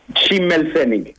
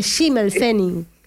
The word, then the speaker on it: Schimmelpfennig: Schimmelpfennig. – Ahí está, viste, Schimmelpfennig.